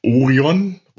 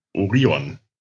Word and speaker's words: Orion: Das heißt natürlich nicht Orion oder Orion, sondern Orion.